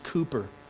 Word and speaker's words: Cowper: His name was William Cowper, spelled C-O-W-P-E-R, pronounced Cooper.